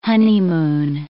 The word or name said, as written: honeymoon